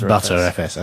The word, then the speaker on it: Btrfs: I can't believe it's not pronounced ButterFS, as in, yeah. – As in the butter.